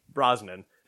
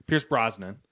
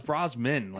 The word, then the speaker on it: Brosnan: Brosnan. It's two N's. – Is it? – Yeah. – Okay. Pierce Brosnan. I thought it was Brosman, like no, M-A-N. – There's two N's.